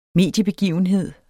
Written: mediebegivenhed

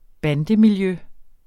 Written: bandemiljø

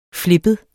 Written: flippet

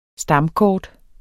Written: stamkort

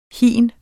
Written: hin

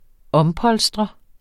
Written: ompolstre

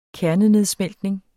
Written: kernenedsmeltning

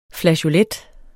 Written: flageolet

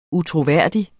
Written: utroværdig

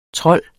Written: TROLDEN

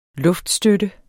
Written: luftstøtte

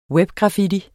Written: webgraffiti

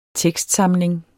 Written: tekstsamling

